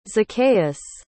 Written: zacchaeus

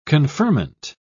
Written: conferment

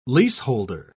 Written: leaseholder

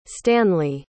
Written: stanley

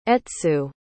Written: etsu

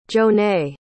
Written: jonay

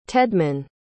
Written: tedman

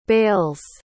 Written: bales

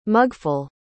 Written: mugful

